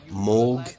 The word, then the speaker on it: Moog: Ah, oh, big Moog sign. I want one of them. Or Moog, as it should be pronounced, apparently, because that was his surname.